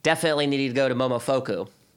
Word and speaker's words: Momofuku: Yeah, it's Momofuku.